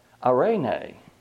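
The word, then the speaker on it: irene (εἰρήνη): And this word peace in the Greek is pronounced something like irene.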